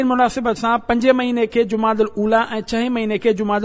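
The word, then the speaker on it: جمادی الاولیٰ: صحیح نالو این صحیح تلفظ لغت جے اعتبار سا انن بینی مہینن جا صحیح این درست تلفظ ہی آہن جماد الاولا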